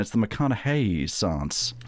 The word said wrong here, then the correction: McConaissance: Nope, it's the McConaissance.